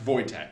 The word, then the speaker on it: Wojtek: For reference, that's spelled W-O-J-T-E-K. – Nice. – It's pronounced Wojtek.